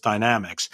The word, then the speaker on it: Dynamex: Most people pronounce it Dynamex, so I've been told it's Dynamex.